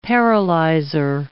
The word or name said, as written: paralyzer